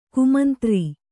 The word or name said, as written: ಕುಮಂತ್ರಿ